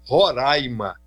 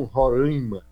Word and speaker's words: Roraima: Aliás, a gente aqui é barrista ao pedir que as pessoas pronunciem Roraima e não Roraima.